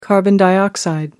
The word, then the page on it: carbon dioxide: American English